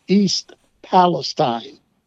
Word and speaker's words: Palestine: And I guess he endeared himself to the people of East Palestine, Ohio, and that's the right way to pronounce it too, not Palestine like we do in Texas up there. They call it East Palestine. That is the correct pronunciation, I guess.